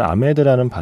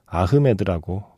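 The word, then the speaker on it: Ahmed: 엄밀히 말하면 아메드라는 발음이 아니라 아흐메드라고 발음하는 게 맞을 겁니다.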